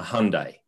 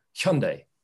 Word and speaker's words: Hyundai: And I had chucked in a Hyundai. And that was a bit tongue-in-cheek because I know that's how that brand is pronounced in the US, for example. – Yeah. – You go to Korea, the way in which Korean people pronounce Hyundai has a bit of a huh in it. So it's a Hyundai.